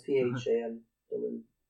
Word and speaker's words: Phandelver: Yeah, it's Phandelver.